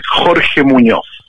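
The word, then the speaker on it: Jorge Muñoz: Well, I mean, uh, it's, it's, that's how people call me in, in Australia, but the real name is Jorge Munoz.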